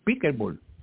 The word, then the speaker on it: Pickleball: Y es es árbitro también de, ¿vieron si lo digo bien? Pickleball. – Así mismo es.